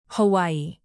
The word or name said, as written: hawaii